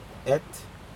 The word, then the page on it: et: Aneityum